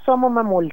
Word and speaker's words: Domo Mamul: Y en esta oportunidad avanzamos también con una nueva canción que está muy potente, que es muy simbólica también, Domo Mamul, la plegaria del pueblo. ¿Lo dije bien? – Se puede decir do o so, es decir, domo mamul o somo mamul también.